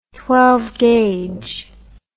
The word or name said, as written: twelvefold